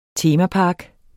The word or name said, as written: temapark